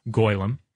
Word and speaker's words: golem: It can also, since this is a Jewish idea, it can mm-hmm. be pronounced goylem, mm-hmm. – Which is, I guess, more in keeping with the Hebrew.